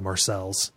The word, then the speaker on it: Marseille: and a town called Marseille is called Marcelles.